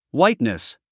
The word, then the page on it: whiteness: hwáitnis; ホワイトネス